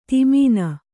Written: ಧಮನಿ

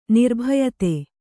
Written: ನಿರ್ಭಯತೆ

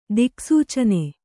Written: ದಿಕ್ಸೂಚನೆ

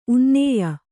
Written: ಉನ್ನಾಯ

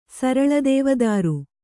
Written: ಸರಲದೇವದಾರು

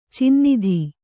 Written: ಚಿನ್ನಿಧಿ

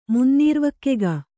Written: ಮುನ್ನೀರ್ವಕ್ಕೆಗ

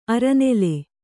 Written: ಅರನೆಲೆ

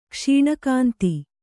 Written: ಕ್ಷೀಣಕಾಂತಿ

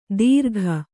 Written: ದೀರ್ಘ

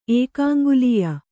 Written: ಏಕಾಂಗುಲೀಯ

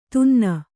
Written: ಧೂನನ

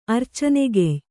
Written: ಅರ್ಚನೆಗೆಯ್

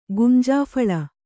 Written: ಗುಂಜಾಫಳ

